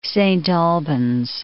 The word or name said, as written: Saint Albans